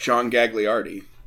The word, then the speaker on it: John Gagliardi: The all-time winningest coach, John Gagliardi.